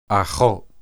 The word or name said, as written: achoth